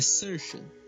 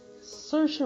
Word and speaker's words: Saoirse: Ela explicou o nome dela, a pronúncia. É Saoirse. – Saoirse Ronan.